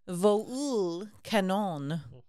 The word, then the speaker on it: VoulKanon: Not like the creature, but like the spelling of soul, S-O-U-L, but with a V. – It's like a real jazzy vole. – So I'm choosing to pronounce it vole, but it could be vol canon.